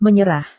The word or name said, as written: menyerah